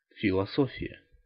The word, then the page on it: philosophie: France; [fi.lɔ.zɔ.fi]